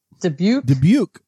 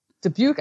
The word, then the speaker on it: Dubuque: Dubuque, Iowa. Dubuque, Iowa.